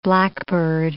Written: blackbird

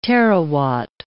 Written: terawatt

